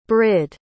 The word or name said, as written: brid